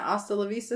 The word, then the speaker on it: Hasta La Vista: Was the worst part about Camp Rock is they sang that Hasta La Vista song and they pronounced the H.